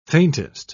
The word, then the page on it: faintest: British English